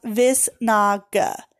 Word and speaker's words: visnaga: This herb is called visnaga, pronounced visnaga.